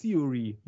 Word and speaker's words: Theory: Heißt er eigentlich nur Fury oder heißt er Fury, wie das Pferd? Nein. Oder wie wird der ausgesprochen? – Theory, wie die Theorie. – Also immer noch, ja, aber nicht Fury, weil viele sagen auch Fury, finde ich so.